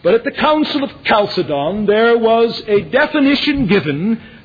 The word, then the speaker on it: Chalcedon: Chalcedon. My dictionary says the proper pronunciation, at least the anglicized pronunciation, is Chalcedon.